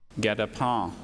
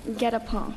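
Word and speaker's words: guetapens: Get a pen. – Get a pen. Is this French? – It's from French. – Get a pen. G-U-E-T-A-P-E-N.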